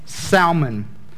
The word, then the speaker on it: Salmon: Scripture tells us that all of the Israelites, that an Israelite, when we look at this story, named Salmon, not pronounced like the fish. The fish is pronounced salmon.